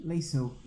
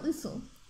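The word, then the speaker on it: Liesl: The oldest is Lyle. Lisla? Lyle? How do you say her name? – Show me. Which one? – The first one. – Lisel. – There you go, Lisel.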